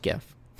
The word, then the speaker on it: GIF: It's like there's only one Jiff. Let's officially settle this. It's pronounced GIF.